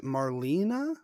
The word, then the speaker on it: Marlena: Basically anyone who is serene, probably, like anyone who's quiet or quiet-ish during the who was it? Marlena? – Yes. – Am I saying that right? – That's who I was. – Yes. – Say.